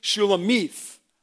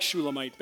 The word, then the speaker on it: Shulamite: Now the one who is the fairest among women is the Shulamite, and according to Strong's, even though I'm not going to obey him, it's pronounced Shulamith. But I like Shulamite better, so I'm going to stick with that.